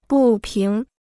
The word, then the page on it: 不平: bù píng